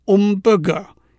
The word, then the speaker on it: hamburger: 至于在法国呢，写法和英文一样，可是发音却是 Hamburger，因为在法文字首的 H 是不发音的。也有人把这个字念成 Ombre，因为 G-E-R 在法文的发音是 she。